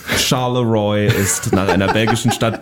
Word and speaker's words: Charleroi: Do I pronounce it right? Charleroi? – Probably for the French, yes. – For the French, but how would the Americans pronounce it? – Oh, it's embarrassing to say in English because it sounds. We say Charleroi. – Charleroi. Well, it's called Charleroi.